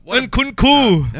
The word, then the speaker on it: Nkunku: What's Mununku? – Nununku. – Nkunku. – Never mind. Lah. Nunkunku. I can't even pronounce it.